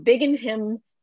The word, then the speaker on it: Binghamton: He's a full-time single girl dad from Binghamton, New York.